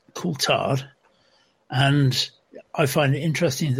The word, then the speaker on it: Coulthard: David Coulthard, um, name is regularly mispronounced as David Coulthard.